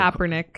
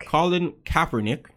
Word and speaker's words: Kaepernick: Kaepernick. – Colin Kaepernick.